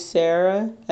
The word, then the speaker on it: Sarah: That's Summer, S-U-M-M-E-R, Girl, G-I-R-L, O-F, 97. And my professional account, which is Sarah _ Journalist. So my name's Sarah, S-A-R-A-H, _, and the word journalist.